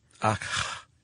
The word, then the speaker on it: Akh: The Ka, the Ba, and the Akh. – Akh. – Yeah. – A-K-H.